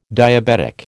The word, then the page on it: diabetic: American English